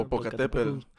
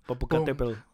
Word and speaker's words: Popocatépetl: Popocatépetl. – Popocatépetl.